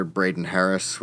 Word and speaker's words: Braden Harris: It's just under Braden Harris, which is B-R-A-D-E-N, and then Harris, H-A-R-R-I-S, you know, how Harris is spelled.